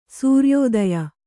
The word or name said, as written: ಸೂರ್ಯೋದಯ